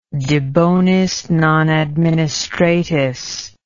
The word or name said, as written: de bonis non administratis